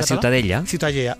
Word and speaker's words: Ciutadella: ¿cómo se pronuncia? – La Ciutadella.